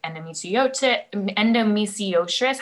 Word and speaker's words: endometriosis: And I think especially as you had mentioned with like endometriosis, how do you say that? – Endometriosis.